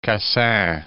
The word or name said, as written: Cassin